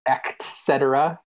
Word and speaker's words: et cetera: The word that people say wrong that bothers me is the word et cetera. People say the word et cetera. – E-C-T. Yeah, there's no X in there. – It's E-T-C, not E-C-T. So... that bothers me when people say that wrong.